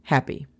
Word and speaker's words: happy: La pronunciación fonética sería C-L-U-L-E-S. Clueless. Number two. Número dos. Happy. Happy significa feliz. La pronunciación fonética sería J-A-P-I. Happy.